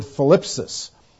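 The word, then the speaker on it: philipsis (θλῖψις): The word tribulation is the word philipsis, with a T-H-L, philipsis, kind of a strange pronunciation for us.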